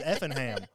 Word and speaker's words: Effingham: and they were pronouncing it Effingham County, Georgia. And I was like, what the fuck? Like Buckingham Palace? I was like, this is Effingham. What's going on Effingham.